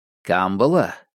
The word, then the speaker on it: Камбала: Камбала — ударение на первый слог, по другому варианту опознается чужак, не дальневосточник, вписана в библейскую историю.